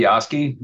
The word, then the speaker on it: Piechowski: And just so the record is clear on your name, it's pronounced Piowski, but spelled Piechowski. – Piechowski. Yes, it's pronounced All right. – Piasky.